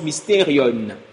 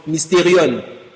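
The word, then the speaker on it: mysterion (μυστήριον): Le mot mystère vient d'un mot grec que l'on prononce mysterion. Le mot écrit ici, là, traduit en français mystère. Derrière, c'est un mot grec que l'on prononce mysterion.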